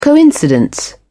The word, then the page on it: coincidence: British English